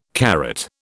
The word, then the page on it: karat: British English